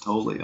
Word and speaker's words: Tolle: Sam says the last name is pronounced Tolle.